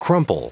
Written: crumple

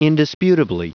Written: indisputably

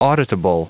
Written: auditable

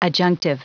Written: adjunctive

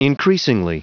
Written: increasingly

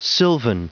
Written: sylvan

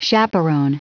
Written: chaperon

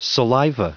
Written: saliva

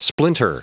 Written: splinter

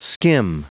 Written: skim

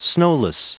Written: snowless

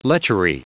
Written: lechery

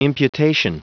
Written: imputation

